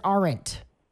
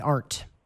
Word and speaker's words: aren't: I say it in two syllables, like Ross. Aren't, to push it all together into one, that's fine, too. That's fine, too.